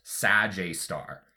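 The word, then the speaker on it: Sgr A*: So, SGR A star, the abbreviation is pronounced Sag A star.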